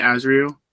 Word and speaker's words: Azrael: Am I saying that right, Azrael? – Mm-hmm, I believe so.